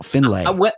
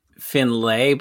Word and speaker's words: Findlay: Findlay, Ohio. Findlay. – When I've seen it written, I always said Findlay, but it can't be it. It's probably just Findlay. – Yeah, it's got to be Findlay, right? It's not. I can't imagine Ohioans saying Findlay.